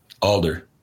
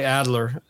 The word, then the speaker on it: Alder: Yeah, but Ray, I always get his last name wrong. – Alder. – Okay. I always say Adler, and I knew it wasn't right.